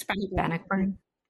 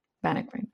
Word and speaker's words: Bannockburn: Yes, Bannockburn. Bannockburn.